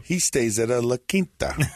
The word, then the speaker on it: La Quinta: And, you know, eating at the local Wendy's, staying at the local La Quinta. Is that how you say it? – La Quinta.